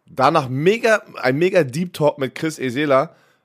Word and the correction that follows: Esala: Esala, Mann, Mist, ich wieder falsch ausgesprochen. Tut mir leid, Chris. Esala hat er gesagt, so wird er ausgesprochen.